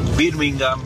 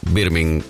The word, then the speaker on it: Birmingham: Todos los equipos que terminan con ham, uh-huh, H-A-M, bueno, la H es muda. Ejemplo, Tottenham, Birmingham. Creo que la única excepción es West Ham, pero justamente porque se escribe separado.